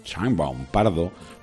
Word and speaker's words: Sheinbaum: No enviar delegación oficial a la toma de posesión de la nueva presidenta mexicana, Claudia Sheinbaum, se dice, ¿no? ¿Cómo se dice? – Sheinbaum. – Sheinbaum, Pardo, por negarse a invitar al acto al rey de España.